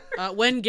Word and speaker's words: wenge: Wenge. It is wenge.